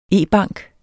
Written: e-bank

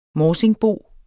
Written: MORSINGBOEN

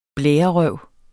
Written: blærerøv

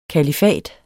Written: kalifat

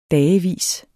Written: dagevis

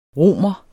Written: romer

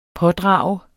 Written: pådrage

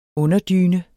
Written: underdyne

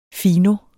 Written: fino

